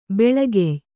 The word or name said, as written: ಬೆಳಿಗ್ಯೆ